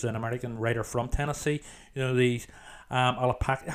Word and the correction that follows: Appalachian: How do you pronounce? I always struggle with this word, the mountains. How do you pronounce? – Appalachian. – Appalachian. Yes, that's it.